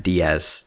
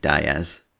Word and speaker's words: Diaz: By the way, I can't allow your pronunciation of Matt Diaz to stand, because Matt Diaz is the only Diaz ever to pronounce his name Diaz.